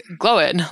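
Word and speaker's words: Glóin: as in Gloin like coin, you are totally correct. correct. If you pronounce it as like glowing, like blowing in the wind, you're also totally correct.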